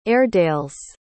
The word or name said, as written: airedales